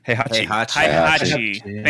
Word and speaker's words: Heihachi: Heihachi.